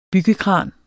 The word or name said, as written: byggekran